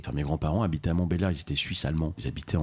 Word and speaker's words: Montbéliard: Montbéliard, effectivement, on prononce pas le ouais. – L.